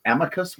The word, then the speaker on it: amicus: I think the accepted is amicus with the emphasis on the first syllable, as we often say.